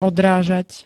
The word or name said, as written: odrážať